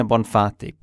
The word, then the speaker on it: Bonfatti: So it's from Marina Bonfatti. Perfect Italian pronunciation.